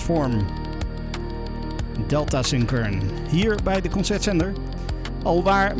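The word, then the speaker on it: Delta Sin Kern (ΔSYN.0X): Het wordt geschreven als driehoekje SYN puntje 0X. Maar het wordt uitgesproken als Delta Sin Kern.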